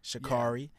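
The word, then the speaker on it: Sha'Carri: So, especially when you tie it into the sports world, for example, the Olympic sprinter Shakaya, right? Is that how you pronounce her name? – Shakari. Yeah.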